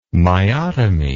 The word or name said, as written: myotomy